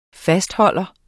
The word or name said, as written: fastholder